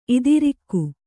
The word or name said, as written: ಇದಿರಾಗು